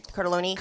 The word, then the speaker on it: Cartellone: Tommy Shaw, Jack Blades from Night Ranger, Ted Nugent, and Michael how do you say that guy's name? Cartellone? – Cartellone. – So it's super Italian.